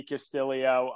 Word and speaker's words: Castillo: We know it's Ricky Castillo.